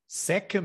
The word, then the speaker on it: Secca: Sim, tu uh, adiantaste o teu apelido, uh, que se escreve S-E-C-C-A, Seca mesmo? Exatamente. Seca mesmo.